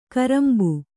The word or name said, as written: ಕೊರಂಬೆ